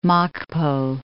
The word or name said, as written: Mokpo